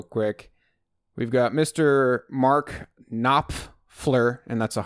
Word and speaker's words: Knopfler: I made sure that's how you say it. It's got a P and an F. Knopfler. Knopfler. You can't say it fast.